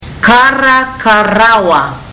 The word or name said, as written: karakarawa